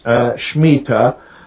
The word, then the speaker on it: Shemitah: Okay, the word is shemitah. It's a transliteration of a Hebrew word. A lot of times you'll see it spelled S-H-E-M-I-T-A-H. But the pronunciation is Shemitah.